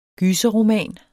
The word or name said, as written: gyserroman